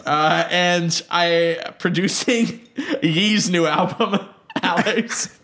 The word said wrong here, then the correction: Ye: Jesus Christ, it's pronounced yay. – They said ye in this. I made a very No, they say it's yay as in Kanye and then Yeezus as in Jesus.